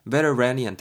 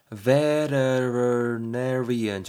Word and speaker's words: veterinarian: veteranian 된다며. 자 여러분 요 단어 좀 발음하기 좀 어렵죠? veterinarian 정도가 되는데요. veterinarian 정도인데 쉽게 말해서 그냥 줄여서 vet, vet 이렇게 줄여서도 많이 쓰고요.